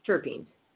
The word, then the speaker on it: terpenes: About 140 of these belong to a large class of aromatic organic hydrocompounds known as terpenes, pronounced terpenes. It's spelled T-E-R-P-E-N-E-S.